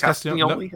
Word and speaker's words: Castagnoli: I don't ever feel like I'm saying that right. It's got to be Castagnoli. – It's Castagnoli. No, you get it.